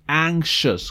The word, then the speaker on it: anxious: Recuerden que la pronunciación anxious, como si fuera una CH, ¿no? Perdón, SH.